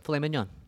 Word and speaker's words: filet mignon: Filet mignon.